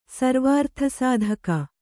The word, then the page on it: ಸರ್ವಾರ್ಥಸಾಧಕ: Kannada